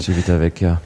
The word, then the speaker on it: Civitavecchia: Okay, das heißt, ihr seid dann von Olbia nach Civitavecchia, oh Gott, das kann ich jetzt nicht aussprechen. – Civitavecchia, ja.